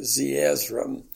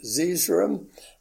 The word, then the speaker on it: Zeezrom: and under Zeezrom, you'll see the pronunciation symbols that tell you to pronounce it Zeezrom. Otherwise, most people pronounce it Zeezrom, and that's fine with me.